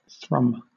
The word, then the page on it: thrum: British English